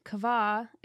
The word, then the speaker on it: qavah: So anyways, I just thought that this word kava is so beautiful. You guys should look it up. It's spelled Q-A-V-A-H, and that's the Hebrew word for hope.